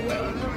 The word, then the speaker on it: weón: y se escribe con H. – Con W. – W, E. E-O-N. – E Ajá. Weón.